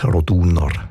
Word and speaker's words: Roduner: In dem Fall ist es aber eher die zweite Silbe, die betont Roduner.